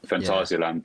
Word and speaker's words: Fantasialand: But yeah, we just always combine it with going to whatever theme park is open over the winter period, and Fantasialand do the winter trim. Is that how you say it? – Yeah, I think so. Yeah, that's the way I say it.